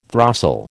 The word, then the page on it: throstle: American English